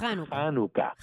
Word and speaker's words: Hanukkah: Es más, en realidad, la forma actual de pronunciar es raspando tu garganta y es Hanukkah. – Sí. Okay. Y entonces, como el campestre cuando dice cajo.